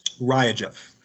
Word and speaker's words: RiaGev: It's is that pronounced RiaGev or RiaGev? – RiaGev.